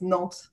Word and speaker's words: Nantes: Yeah, it's Nantes in French.